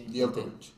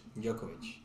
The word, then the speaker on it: Djokovic: De Ojovic, no sé si se dice así. El tenista. Sí, sí, sí. Djokovic. Djokovic. Gracias.